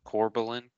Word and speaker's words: Corbillon: Can you spell it for me? – Yeah. Corbillon. – Okay. – Corbelin Cup.